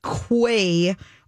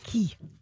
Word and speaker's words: Quay: But meanwhile, she is launching her second collection with Quay Australia. – Not pronounced Quay, it's pronounced Key.